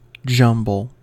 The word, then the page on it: jumble: American English